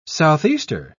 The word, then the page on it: southeaster: British English